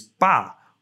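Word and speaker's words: ba (八): In Mandarin Chinese, the pronunciation of 8 is ba, which is close to fa, meaning to make fortune.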